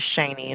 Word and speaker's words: Shaney: My name is Shani, and it's spelled S-H-A-N-E-Y.